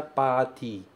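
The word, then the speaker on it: pati (パーティー): Eu não falo ti, por exemplo, no meu dia a dia. Mas aqui eu tenho que pronunciar pati.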